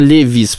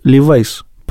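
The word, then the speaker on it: Levi's: я думаю, что он Левис, потому что это Леви. – Справка. Согласно словарю Брагауза и Ефрона, слово «левис» произносится как Левайс, потому что он Левай.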